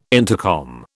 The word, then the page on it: intercom: British English